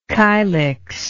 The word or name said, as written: kylix